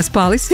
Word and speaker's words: спалось: Я очень надеюсь, что всем остальным вам прекрасно спалось. Спалось, как говорят, вот так надо правильное ударение ставить.